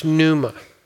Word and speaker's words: pneuma: And the word spirit, pneuma, or sometimes pronounced pneuma, the word spirit is not just air.